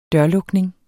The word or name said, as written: dørlukning